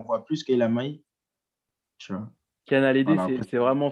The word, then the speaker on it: Kiana Ledé: en euh, a le D, je sais pas si je le dis bien, Kiana Ledé. – Ouais, Kiana Ledé.